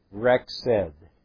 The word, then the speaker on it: Rexed: Notice the pronunciation. Rexed. Rexed.